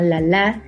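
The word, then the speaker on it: Lalá: Eh, vámonos con una canción de una artista peruana que amo mucho, se llama La La LALA con acento en la última A.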